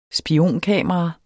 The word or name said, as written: spionkamera